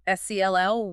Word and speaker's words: SEL: When we say social emotional learning or SELL, what are we actually talking about? – So SEL, and you'll hear us pronounce it SEL,